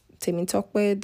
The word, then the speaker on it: Temitokpe: My first name, a lot of people really have a hard time pronouncing the last syllable, especially like the Tokpe. They mostly just call me Temi, honestly, because Temi Tokpe, the way they pronounce it, that don't even sound like my name no more.